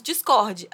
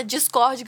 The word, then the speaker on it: Discord: Aliás, semana passada eu disse que eu ia fazer a enquete, né, sobre Discord ou Discord. Fiz mesmo. A pronúncia Discord ganhou com 75% dos votos. Então, tô decidida. Agora pra frente, pra mim, é só Discord.